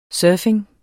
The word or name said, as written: surfing